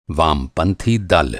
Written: वामपंथी

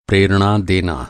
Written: प्रेरणा

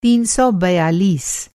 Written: तीन सौ बयालीस

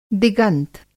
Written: दिगंत

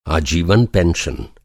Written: आजीवन

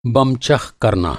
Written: बम-चख़

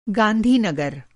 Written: गांधीनगर

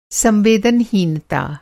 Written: संवेदनहीनता

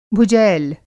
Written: भुजैल

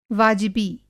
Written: बाजिबी

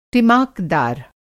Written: टिमाकदार

